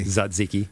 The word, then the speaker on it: Tzatziki: So first of all, how do you actually properly pronounce tzatziki, which is how I say it? – Tzatziki? – Tzatziki. Yeah. Paul, make note. – Kill the T. – Tzatziki.